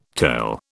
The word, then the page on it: tale: British English